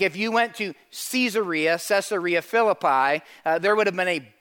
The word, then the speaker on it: Caesarea Philippi: Verse 27, he and his disciples went on to the villages around Caesarea Philippi. Everybody look here. You can also pronounce that Caesarea Philippi.